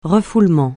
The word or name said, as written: refoulement